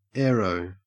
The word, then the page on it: aero: Australian English